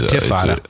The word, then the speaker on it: Intifada: And the reason they want to do that, because this Infatata app basically is... – Intifada. – Intifada, is that how you pronounce it? – Yeah, the T's before the F.